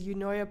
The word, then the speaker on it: Eunoia: Well, the name of our podcast is You Know Ya Podcast, which is spelled E-U-N-O-I-A.